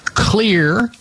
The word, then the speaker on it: Xlear: Number one, go for Clear, X-L-E-A-R, Max, M-A-X, nasal spray. This is mostly for adults. Clear, Max nasal spray. Clear is spelled with an X.